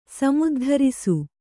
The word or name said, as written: ಸಮುದ್ಧರಿಸು